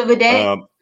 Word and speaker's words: Vidette: Like I said, your senior, you realized that the media outlet that you're working for, Divided. – The Vidette? – The Vidette. I'm sorry. Mm-hmm. I said it wrong.